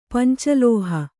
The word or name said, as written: ಪಂಚಲೋಹ